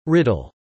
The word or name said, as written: riddle